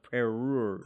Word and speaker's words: parure: Perrure. – Brooch-lacelet. I love that. I don't get... – Is that P-A-R-U-R-E? – It's P-A-R-U-R-E, and the pronunciation says P-E hyphen and then R-O-O-R, which is perrure, which is a set of jewels intended to be worn together.